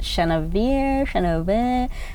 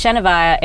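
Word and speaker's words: Shenavyre: Is it Shenavir? And we'd be like, oh, okay, so it's Shenavaya, it rhymes with fire.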